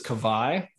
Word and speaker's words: Kawai: K-A-W-A-I. Yeah. What is that? – Yeah, so that's actually the way to pronounce it is Kawhi. The W is like a V, and it's Hawaiian for that particular part is the water.